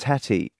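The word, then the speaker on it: tattie: It's pronounced tatty.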